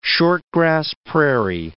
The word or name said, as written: short-grass prairie